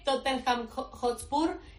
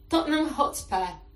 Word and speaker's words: Tottenham Hotspur: No es Tottenham Hotspur, es Tottenham Hotspur.